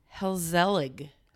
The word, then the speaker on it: gezellig: It's, let me try and say it. Helzellig. – I wasn't even close. – But it's spelled with a G. So G-E-Z-E-L-L-I-G.